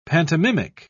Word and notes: pantomimic: British English

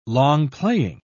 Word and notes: long-playing: British English

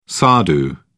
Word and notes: sadhu: British English

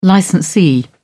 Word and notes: licensee: British English

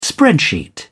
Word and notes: spreadsheet: British English

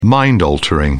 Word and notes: mind-altering: British English

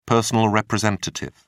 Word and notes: personal representative: British English

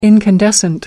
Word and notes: incandescent: American English

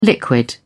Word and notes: liquid: British English

